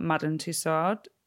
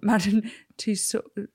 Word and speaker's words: Tussaud: My favourite bit was actually the whole area where we found out more about Madame Tussaud. Oh, saying it wrong. Madame Tussaud.